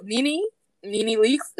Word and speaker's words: Nene Leakes: You know, people like People like, oh, Nene Leakes, is that how you say her name?